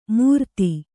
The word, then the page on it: ಮೂರ್ತಿ: mūrti